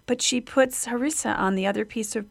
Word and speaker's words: harissa: and do you say harissa, or how do you pronounce it? – Yes, I think it's harissa.